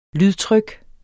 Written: lydtryk

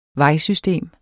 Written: VEJSYSTEMET

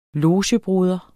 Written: LOGEBROR

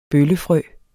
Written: bøllefrø

